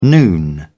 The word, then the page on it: noon: British English